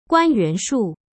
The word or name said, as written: guanyushu